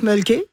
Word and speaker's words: Molkky: Alors, ça s'appelle le mulky. On prononce mulky. – Ouais. – Et c'est M-O-L-K-K-Y.